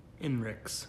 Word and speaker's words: INRIX: The report, INRIX's, it's INRIX. How would you say that? – INRIX. – INRIX.